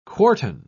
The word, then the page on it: quartan: British English